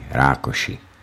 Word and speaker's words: Rákosi: Matias Rákosi, nebo výslovností Rákoši, Žid jménem Matthew Todd Rosenkrantz, komunistický vůdce Maďarska let 1945 až 1956.